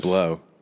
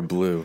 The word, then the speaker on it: Blau: This restaurant in Ormond called Blau, B-L-A-U. – Never heard of it. – Yeah, it's Mediterranean. – I think it's pronounced Blow. – Blow. – Or Blue. – I think it's Blau, because I called him to make a reservation. They said, hello, Blau. – Welcome to Blau.